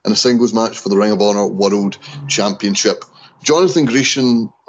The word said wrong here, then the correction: Gresham: or Griesham, am I pronouncing that right? – Gresham.